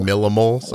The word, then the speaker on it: millimoles: How do you guys say MMOL? Millimoles. Okay.